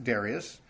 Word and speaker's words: Darius: It says, in the 8th month of the 2nd year of Darius, or some might pronounce it Darius,